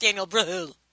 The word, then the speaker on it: Brühl: I believe it's pronounced Daniel Brühl. I mean, it has an H in there, so what, do you not pronounce the H? – Like an umlaut? – Exactly.